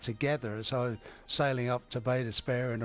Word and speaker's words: Bay d'Espoir: So I was, because it was French, I was pronouncing it Bay d'Espoir. And the guys said, no, we don't call it that. We call it Bay d'Espoir.